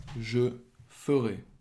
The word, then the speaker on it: je ferai: It's F-E-R. And it's pronounced in the sense of I will do is je ferai.